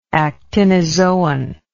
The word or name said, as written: actinozoan